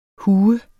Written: hue

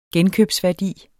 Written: genkøbsværdi

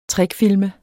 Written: trickfilme

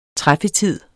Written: træffetid

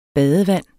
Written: badevand

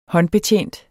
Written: håndbetjent

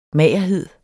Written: magerhed

